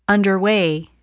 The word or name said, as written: underway